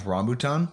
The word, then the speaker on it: rambutan: See, I think it's pronounced rambutan. – There we go.